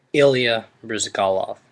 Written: Ilya Bryzgalov

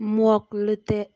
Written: Mmuock Leteh

